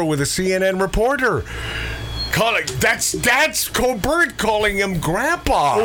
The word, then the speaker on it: Colbert: I know how it's pronounced. – Oh my God! Did he just now catch up? – No, I'm just being proper. – Yeah, thank you. No, proper is Colbert.